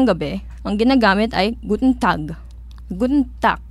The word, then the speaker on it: Tag: Kasi yung pagtatandaan natin sa German kasi, yung pronunciation nila mostly ng G kapag dulo, parang K. So, tag. Hindi siya tag. Hindi siya babasay na tag. Tag.